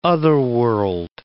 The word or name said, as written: otherworld